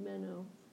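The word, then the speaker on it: meno (μένω): Meno. Mm-hmm. See that? There's the mu and the epsilon, and then that b is a nu, and then the omega, the long O sound, meno.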